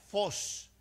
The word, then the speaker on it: phos: Si somos luz del mundo, la luz viene, esa palabra luz viene de, un, de una raíz griega que se pronuncia phos, P-H-O-S, phos, y eso significa, en nuestro idioma español, significa fuego y significa brillantez.